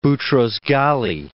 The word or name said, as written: Boutros-Ghali